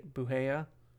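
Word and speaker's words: Bugeja: One outgoing transfer this week, Haley Buhea. Did I say that right? – Transferred. I believe so.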